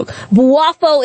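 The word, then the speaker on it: Boafo: I always say his name wrong. Buafo is how you say it, okay? That's how you pronounce it.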